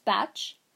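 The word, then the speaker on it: Bach: Clematis, a batch remedy, this is pronounced batch, is effective for those of us who are asleep in dreamland.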